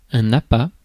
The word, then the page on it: bait: American English; [beɪt]